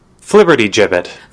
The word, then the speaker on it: flibbertigibbet: Would you guys think that I'm a flibbertgibbet? – Flibbertigibbet. – Flibbertigibbet! – Flibbertigibbet! Flibbertigibbet. – If you read the pronunciation guideline.